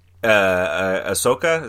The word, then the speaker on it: Ahsoka: And so it's stuff like Ahsoka, is that how you say her name? – Yeah.